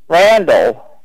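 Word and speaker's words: Randal: Randall Stymie. – Okay. What was the first name? – Randall. – Okay. Spell that for me. – I think they spell R-A-N-D-A-L, I think. – Landon? – Randall. – Okay, and the last name? – Stamie, S-T-A-M-E-Y.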